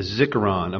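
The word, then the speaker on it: zikkaron: The first one is a zikaron, a memorial.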